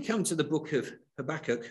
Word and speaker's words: Habakkuk: We come to Habakkuk, and there's different ways of pronouncing that, but I'm going to stick with Habakkuk. I'm English.